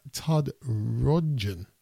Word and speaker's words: Rundgren: It's a rendition of a great, well, it's Todd Lundgren, really. Todd Lundgren. I'm thinking about Dolph Lundgren. Todd Rundgren.